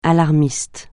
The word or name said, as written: alarmiste